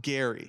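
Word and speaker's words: Gerry: Spelled like Jerry, though, with a G. – I had to do my research to figure out how this was pronounced. It is pronounced Gary.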